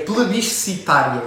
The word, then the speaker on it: plebiscitária: Depois temos a democracia plebiscitária, esta palavra acima é uh, difícil de pronunciar. – Quando eu estava assim, eu para dizer assim, você já ouviu falar da democracia? Não, é plebiscitária.